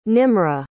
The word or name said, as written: nimra